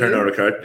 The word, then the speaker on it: Pernod Ricard: Pernod Ricard.